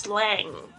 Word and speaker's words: slang: 所以呢，但是你在学英文的时候，你一定要记住这个 ng 的发音是这个后鼻音。像就是我最近有听到一个广播，就是老师是教这个 English slang，但是 slang 这个字呢，它就念得不好，它就是 English slang，